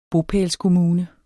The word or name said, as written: bopælskommune